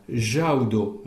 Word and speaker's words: jaudo: Alors, jaudo, hein? Jaudo, il n'y a que deux syllabes. Il y a jau, la syllabe accentuée, et do, la syllabe terminale. Jaudo.